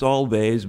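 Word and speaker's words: Alvvays: That's Always, A-L-V-V-A-Y-S, a little spelling. – Challenge. It's not always. – Everybody wants Always, but, you know, yeah. It's Always. That's the way they pronounce it.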